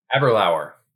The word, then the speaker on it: Aberlour: Is it Aberlour? – Aberlour. – Aberlour. All right.